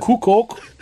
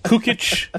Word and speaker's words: Kukoc: Kukoc. – Yeah, Kukoc. – Kukoc. Kukic.